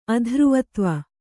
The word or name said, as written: ಅಧ್ರುವತ್ವ